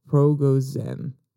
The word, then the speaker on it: Prigozhin: Progozen, not Zev, Zen, Progozen, according to Newsweek.